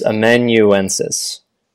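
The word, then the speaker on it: amanuensis: Before we go on, people have been complaining that you pronounce that word incorrectly. It's Amuensis. There are more syllables in it than you have been saying.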